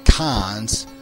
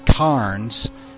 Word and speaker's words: Cairns: it's a strange place in that it's pronounced like 17 different ways. It's spelled C-A-I-R-N-S, and we usually pronounce it Cairns. Some people say Cons. Some people say Carns. Other people say potato.